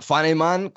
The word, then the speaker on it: Funnyman: Fanaiman è una delle canzoni, ma sono certo tra poco ne arriveranno anche altre. L'ultimo minuto, qualcosa da aggiungere? – ma Funnyman con l'accento sulla uh, seconda A, perché molti, quando è uscita la canzone, molti, ma tantissime persone, non la trovavano.